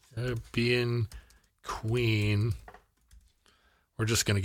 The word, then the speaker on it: Caribbean: Caribbean. Okay. He's from the, you really put the rib back in Caribbean.